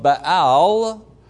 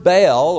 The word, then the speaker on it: Baal: Jezebel was from Tyre, and through her influence, the worship of Baal Melquart, who was the official nature god of Tyre, was being actively brought into the kingdom. Now, the word Baal, or Baal as it's pronounced, means master or possessor or husband.